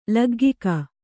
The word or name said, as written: ಲಗ್ಗಿಕ